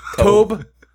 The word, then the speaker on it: Tobe: Toby Hooper does this. – You mean Tobe? – Tobe. – There's no accent on the E, so people, it's Tobe.